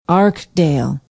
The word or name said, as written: arkdale